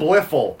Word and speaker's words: Bliffle: And he's Thwiffle? Is that how it's said? – Bliffle. – Bliffle. – B-L-I-F-L.